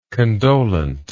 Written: condolent

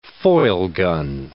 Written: Feulgen

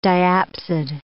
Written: diapsid

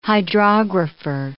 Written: hydrographer

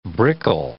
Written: brickle

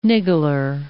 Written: niggler

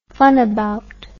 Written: funabout

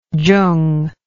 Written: Chung

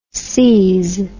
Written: ses

